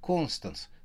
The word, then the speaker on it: Констанс: Меня зовут Констанция, но тетя зовет меня Констанс с ударением на первый слог.